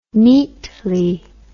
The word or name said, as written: neatly